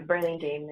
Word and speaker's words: Burlingame: We have Covina, Glendale, Burlingame. Yeah, with a G. There's a G in it. So is it Burlingame? – Yeah, Burlingame is okay.